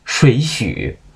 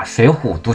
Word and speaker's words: 水浒: 水许，我当时愣了半天。我说我只在相声里头听过人家把水浒读成水许，没想到我身边这么亲近的人也能读成水许，还是中文系。